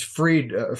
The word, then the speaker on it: Fried: Is his last name pronounced Fried or Fried? – It's Fried, Fried, Bankman Fried.